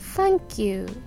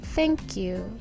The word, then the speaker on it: thank you: Thank you じゃなくて Thank you なんですよで 1 回目か 2 回目にも触れたと思うんですけど and の発音のこの最初の A と一緒で何ていうか「え」と「あ」の間の音みたいな感じ